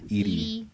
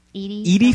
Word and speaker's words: Edie: Eddie Falco for Nurse Jackie. – Edie. You mean Edie? – Huh? – Edie Falco. – Everyone pronounces it Eddie.